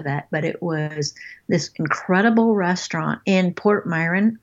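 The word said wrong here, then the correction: Portomarín: I'm probably not saying that right either. – Yeah, Porto Marin, yeah.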